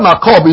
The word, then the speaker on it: makob: The word sorrow is makob, M-A-K-O-B, makob. Now, listen to this. This is important. The word holy is used 24 times. And when it is used for physical sickness. The word makob is used 16 times.